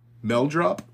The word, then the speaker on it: Meldrop: Today's word is... – Meldrop. – Meldrop? – Yeah. – It's spelled M-E-L-D-R-O-P. Mm-hmm. Meldrop.